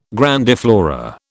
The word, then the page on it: grandiflora: British English